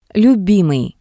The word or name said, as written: lyubimii